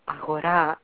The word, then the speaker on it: Agora: It's a movie, Agora, or Agora, if I pronounce it the Greek way.